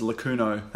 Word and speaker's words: Lakuno: my artist name is Lacuno. That's spelled L-A-K-U-N-O.